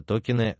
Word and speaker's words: токен: Токен ударения в слове. Ударение в слове ставится на О.